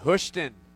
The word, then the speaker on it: Houston: Yeah, man. Houston. Not Houston or Houston. Houston.